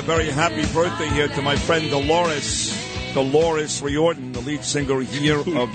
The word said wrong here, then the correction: O'Riordan: Oh, how do you say it? – O'Riordan. O'Riordan.